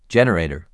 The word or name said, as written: Generator